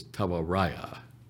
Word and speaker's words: Tawaraya: Uh, the name of it is Tawaraya. Uh, that's the way we would pronounce it T A W A R A Y A.